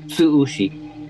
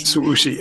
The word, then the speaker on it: Tsu-ushi: Is it Tsu-ushi? – Tsu-ushi, yes. – Tsu-ushi. It sounds quite Japanese.